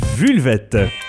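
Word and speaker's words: Vulvettes: Oui, et on me dit qu'il faut le prononcer en français parce que c'est un groupe francophone, donc Vulvette.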